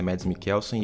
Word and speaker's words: Mads Mikkelsen: E só uma coisinha, em off você falou que tinha procurado a pronúncia e falado que o certo é Mads Mikkelsen e você falou Mads Mikkelsen umas 87 vezes nesse episódio, então...